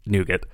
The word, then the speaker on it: nougat: I'm going to quickly address something that I'm starting to feel is the elephant in the room, and that's the way you're choosing to pronounce nougat. – Nougat is the French way to say it, and I was raised by a francophone, so I was actually going to say it's totally fine.